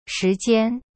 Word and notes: 时间: shi jian

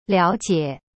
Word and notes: 了解: liao jie

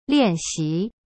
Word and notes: 练习: lian xi

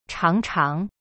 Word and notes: 尝尝: chang chang

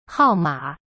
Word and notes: 号码: hao ma

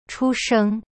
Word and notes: 出生: chu sheng

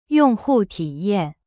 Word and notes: 用户体验: yong hu ti yan

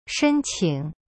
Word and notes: 申请: shen-qing

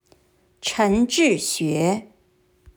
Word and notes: 陈志学: Chen Zhixue

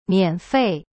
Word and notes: 免费: mian-fei

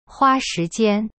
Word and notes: 花时间: hua shi jian

